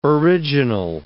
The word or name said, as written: original